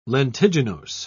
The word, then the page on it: lentiginose: British English